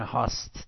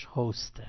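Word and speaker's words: host: ایرانی‌ها به غلط میگن هاست. دوستان لطفاً از کلمه هاست استفاده نکنید، خیلی ازش بعدم میاد، تلفظ درستش هاسته. هاست همتون بگید هاست.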